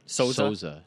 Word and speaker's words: Souza: I mean, I can't hop off the Jacare chain now, so Jacare Souza. – Wow. By the way, say his name correctly. – Souza. I'm sorry.